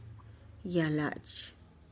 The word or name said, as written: yalachih